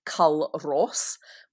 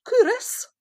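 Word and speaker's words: Culross: You know, a lot of our listeners have been to what they might pronounce as Cull Ross, which in Fife is called Cúras.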